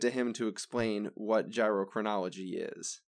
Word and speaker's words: gyrochronology: Another way to measure the age of a star is gyrochronology. Not gerontology. It's not gynecology. It's not geochromatography. No, it's gyrochronology.